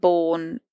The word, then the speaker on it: Bourne: I'm Clare without the I and Bourne is like the films with a U.